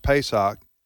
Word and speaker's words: Pesach: Pronounced Pesach in English.